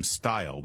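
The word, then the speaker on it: Steil: It's too bad that the congressman from Wisconsin pronounces his name Stile.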